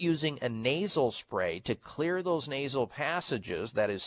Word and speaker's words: Xlear: It's called Clear Nasal Spray, X-L-E-A-R, Clear Nasal Spray. And this has in it xylitol. That's why it's spelled X-L-E-A-R. The X is for xylitol.